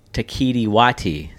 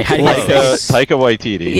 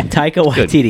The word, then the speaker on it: Taika Waititi: You make a great point, but Ragnarok was very Taika Waititi. Is that my saying his name right? – No. – Okay. – Taika Waititi. – Yeah. Taika Waititi.